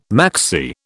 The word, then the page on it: maxi: British English